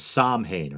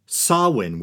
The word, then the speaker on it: Samhain: spelled and often mispronounced as Samhain or Samhain. Samhain,